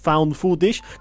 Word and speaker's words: found footage: Lo, pronuncio, lo con la exclusividad que tiene el idioma inglés, es found footage, que justamente para aquellos que no saben, la traducción más fiel de found footage es material encontrado.